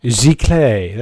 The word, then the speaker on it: Giclée: Giclée.